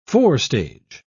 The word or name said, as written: forestage